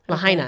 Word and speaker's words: Lahaina: Lanahana? I'm probably saying that wrong. Lahaina. Okay. Lahaina. in old Lahaina.